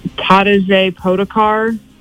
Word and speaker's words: Tadej Pogačar: The men's was won by, man, I can never say this guy's name, Tadej Pogačar.